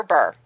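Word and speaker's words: Les Baer: Is it Bear or Bar? How do you pronounce Bauer? – That? – Les Bauer? Les Bear? – Bar. – Gonna have people yelling at their radio right now. – They're going, it's Bar!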